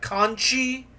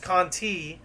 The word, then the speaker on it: Conti: So, you know, there's the, what's her name, Tamara Conti, and he's, like, pronouncing her name like Conchi, and it sounds a lot more like, I don't know if you're familiar with the Spanish word for shell, but it sounds a lot more like that word than it does, like, the name that it is. And I've known people who have the last name Conti, and it's usually just pronounced Conti.